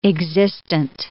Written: existent

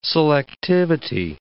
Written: selectivity

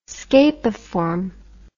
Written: scapiform

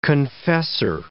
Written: confessor

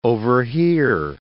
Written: overhear

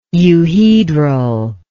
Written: euhedral

